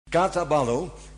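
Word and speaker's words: kataballo: It is Greek, so I pronounce it in the Dutch way. Katabalo.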